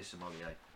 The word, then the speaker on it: sommelier: And Natalia as well, Beers with Nat, who's a beer sommelier.